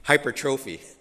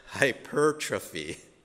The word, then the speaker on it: hypertrophy: I have trouble pronouncing it because I look at it, it looks like you're supposed to pronounce it, at least to me, hypertrophy. But I think it's properly pronounced hypertrophy.